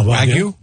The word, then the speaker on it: wagyu: Wagyu? – Yeah. Wagyu.